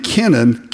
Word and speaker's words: Kenan: It's really the name Kenan, K-E-N-A-N. It's not like Cain.